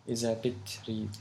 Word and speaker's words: إذا بتريد: Iza, first syllable, bit, and then read. Bit, read. So the last bit is like reading, you know, the word read. Iza bit, read.